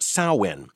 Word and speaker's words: Samhain: It is spelled S-A-M-H-A-I-N, but pronounced Samhain.